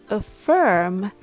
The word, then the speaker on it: affirm: Please note that the noun affirmation and the verb affirm has a different rhythm, or the stress is on a different syllable. The word affirm has the stress in firm. So the a becomes a schwa sound.